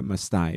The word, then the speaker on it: Mastai: In 1832, the still very young Bishop Mastai was, I realize I just totally butchered his last name earlier, but Giovanni Maria Mastai is his name.